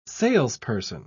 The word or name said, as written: salesperson